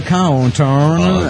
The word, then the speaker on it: Cawthorn: Bueno, esto según Nigel Cawthorn, que es Nigel Cawthorn, así se pronuncia, disculpe.